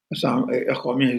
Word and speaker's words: Akhomia: They say Akhomia is the right pronunciation and the right word for, you know, the, these people, for Assam.